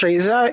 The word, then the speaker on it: Reza: Uh, I mean, I think it's is it hard to pronounce Reza if you are.